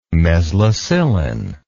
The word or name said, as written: mezlocillin